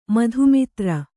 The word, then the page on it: ಮಧುಮೂತ್ರ: madhu mūtra